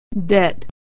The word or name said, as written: debt